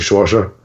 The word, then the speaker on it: Saoirse: Uh, Saoirse Ronan, that's how you pronounce her first name, I believe. Saoirse.